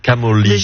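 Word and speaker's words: Camogli: Camoli.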